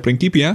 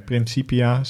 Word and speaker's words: Principia: Waarom zeg je nou Principia? Ja, dat is eh, de Latijnse uitspraak. Sorry, Principia, sorry.